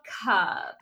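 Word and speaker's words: Kubb: But it's called Cub. – Will tell me, yeah, how to spell it, and we will put it in the show notes. – Yeah, it's K-U-B-B, and it's a long game.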